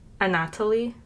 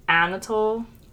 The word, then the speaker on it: Anatoly: Anatoly. – Okay. – It's not Anatole. – I know. – But it's close. It's like Anatole with a Y. So I say Anatoly.